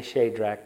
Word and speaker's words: Shadrach: And I say Shadrach, that's how it's actually pronounced.